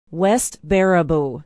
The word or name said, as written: west baraboo